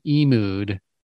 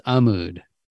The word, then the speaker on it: Amud: By the way, I mispronounce it like three times as Emud, but it's Amud.